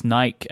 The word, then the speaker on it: Nike: That reminds me, like, how do you pronounce like the brand Nike, right? You say Nike. – Yeah, Nike. Yeah. But in England, it's Nike, and people get super mad.